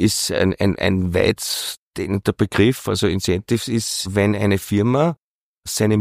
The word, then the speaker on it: Incentive: Ihr bietet auch Inventiv-Reisen an, wenn ich das richtig ausspreche. Was Incentive. – Das? Incentive, Entschuldigung.